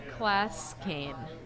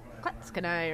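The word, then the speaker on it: Clatskanie: I've read the word a bunch of times, but it looks like it says Klatskane, but it's not pronounced that way. And I looked it up on YouTube, and there was like, the mayor of this town does a walk around to attract businesses, and she's like, Klatskanai,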